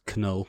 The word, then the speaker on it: Knull: I believe it's pronounced Knull. – Knull, probably. You're probably right on that one, because silent letters are not a thing. – No, don't exist. – KNs are never, ever silent.